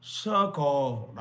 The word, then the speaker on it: circle: mình hay nói cycle cycle cái bambi nó kêu ba ba phải phát âm là circle